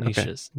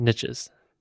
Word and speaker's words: niche: I have one more question about niches. Okay. Niches. – Niche. – Niche. – Rhymes with quiche.